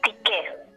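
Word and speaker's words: Tyche: Lacan lo va a llamar eh, con una palabra, con un término griego, que es tiché. Se escribe tiche. – Tiche. Tiche, sí, con Y. T-Y-C-H-E. Esta tique es una palabra que viene de Grecia que denota, em, de la Grecia antigua, ¿no?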